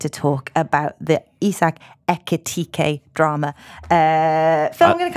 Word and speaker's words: Ekitike: The Reds is your first category, and your options were the Isak Etik. Right, so I'm gonna need to say it properly. Ekitike. Ekitike.